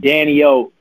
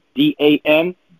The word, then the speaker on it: Dan-E-O: All you got to do is to spell it right, to hear it right. Go check me out online. Danio, Dan, Dan-E-O. I know it's a weird spelling. Trust me. People be on me about it, but that's how it's spelled.